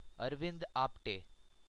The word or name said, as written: Arvind Apte